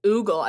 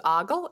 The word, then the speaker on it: ogle: I think is the correct way to say it, is what she's saying. I said it was oogle, I think, and you said it was ogle.